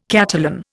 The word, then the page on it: Catalan: Indian